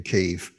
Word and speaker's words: Kyiv: Well, yeah, I do believe that some supermarket chains have rebranded their products to chicken Kyiv, right. – As I believe Kiev is the Russian pronunciation.